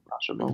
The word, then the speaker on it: Rashomon: But Akira Kurosawa's Rashomon. Did I say that correctly? – Rashomon.